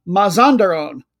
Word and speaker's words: Mazandaran: The more full name is FC Nasaji Mazandaran. No, I want to get my pronunciation right. I wrote it down for a reason. Mazandaran. That's what it is.